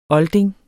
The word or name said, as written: olding